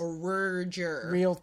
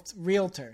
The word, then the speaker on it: realtor: A realtor. – Realtor. – Realtor? – The A is before the realtor. – The realtor. – No? I say it how I want to say it. – Two syllables.